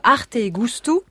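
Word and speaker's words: Arte e Gusto: Et c'est là que se déroulera le week-end prochain la 11e édition de Arte et Gusto. – Ah oui, ouais. Un sacré festival. Un festival. Vous prononcez avec l'accent en plus. – C'est vrai, je le dis bien ? – Pas mal, ouais.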